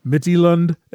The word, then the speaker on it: Midtjylland: All right, so on this episode, we're going to talk about the visit from Sheffield United, a visit from Midtjylland, as Brian has told us it is pronounced according to his friend who spent a year in Denmark.